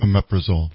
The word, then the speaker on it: omeprazole: Write for your doctor that if you take a pump inhibitor like, say, omeprazole, I never say it right. – Omeprazole. – Thank you, omeprazole.